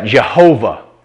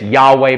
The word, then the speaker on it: Jehovah: We pronounce that Jehovah. Some people say it's Yahweh, but that's getting into German scholarship. I don't even want to go down that road. That is incorrect in my opinion.